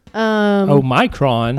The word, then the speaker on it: Omicron: Omicron. That's how it's pronounced.